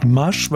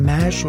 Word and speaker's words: MASH: Jetzt habe ich ein Problem, wie spricht man die aus? M-A-S-H. – Ich würde sagen Masch, weil ich habe auf die Schnelle Masch rausbekommen.